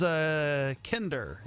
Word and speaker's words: Kinder: Here's Kinder. Am I pronouncing it correctly? – That's right. Just like kindergarten.